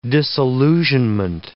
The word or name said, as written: disillusionment